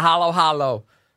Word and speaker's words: halo-halo: Let's talk about the halo-halo.